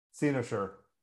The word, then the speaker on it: Cynosure: How'd you pronounce this? Cynosure? – Cynosure. – Thank you. – Cynosure. Yeah. – Dinosaur. Got it.